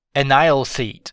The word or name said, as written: an isle seat